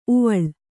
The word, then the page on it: ಊವಾಳ್: ūvāḷ